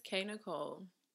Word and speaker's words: K Nicole: So my name is K Nicole, emphasis on the K because it's important. Please don't forget the K because my name is not Nicole, period.